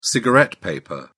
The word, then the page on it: cigarette paper: British English